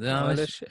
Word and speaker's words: Eilish: Isn't it Billie Eilish? – Is it Billie Eilish? Is that pronounced? – Eilish. Eilish. Okay. Eilish, yeah.